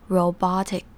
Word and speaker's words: robotic: 这边有两个 O 第一个 O 是念成长母音 O 的发音而第二个 BO 那边的 O 则是念成 R 的发音所以是 robotic